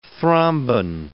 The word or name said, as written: thrombin